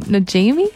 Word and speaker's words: Najimy: I don't know how to say her last name. Najimy? – Yep.